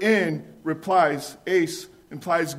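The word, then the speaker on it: ace (εἰς): The word used here, which says believe in, the word in is pronounced ace, E-I-S is how we would spell it.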